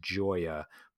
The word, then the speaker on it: Gioia: I think you pronounce his last name Gioia, but it's G-I-O-I-A. So Ted Gioia, G-I-O-I-A.